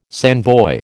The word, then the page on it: sandboy: American English